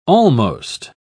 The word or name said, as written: almost